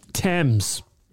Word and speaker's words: Thames: For example, you don't pronounce the H in Thomas. – Or Thames. – Yeah, the Thames River.